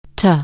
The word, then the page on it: to: anglais